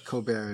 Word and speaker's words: Colbert: Colbert is exactly the way I should pronounce the name, and not what I was doing.